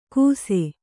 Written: ಕುಸೆ

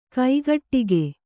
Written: ಕೈಗಡಿಕ

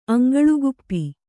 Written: ಅಂಗಳುಗುಪ್ಪಿ

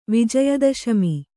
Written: ವಿಜಯದಶಮಿ

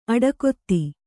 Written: ಅಡಕೊತ್ತು

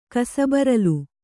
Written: ಕಸಬರಲು

